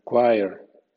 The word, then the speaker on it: choir: CH, O y R suena choir.